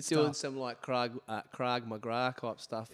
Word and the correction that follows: Krav Maga: What's the actual pronunciation there? – I think it's called Krav Maga. – Krav Maga.